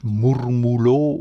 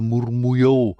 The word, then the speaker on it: Murmullo: Wir haben jetzt noch den Buena Vista Social Club mit Murmulo oder Murmullo. Ja, das wird in verschiedenen spanischsprachigen Ländern verschieden ausgesprochen, dieses Doppel-L. Manchmal mit LJ, manchmal nur mit J, manchmal auch einfach mit Doppel-L.